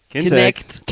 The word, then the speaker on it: Kinect: Unter anderem natürlich das ehemalige Projekt Natal, was jetzt Kinetic heißt. – Falsch, nee. du hast das schon falsch Mäh. gesagt. Noob. Das habe ich falsch gesagt. Kintech. – Kintech. – Kinect. Kinect, verdammt. Kinect.